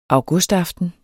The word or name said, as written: augustaften